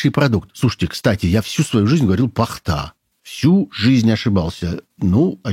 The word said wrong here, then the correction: пахта: Во всех словарях написано, что правильное ударение на первый слог – пахта.